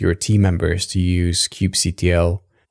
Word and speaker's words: kubectl: I always say Kubectl. – Kubectl. Okay, good.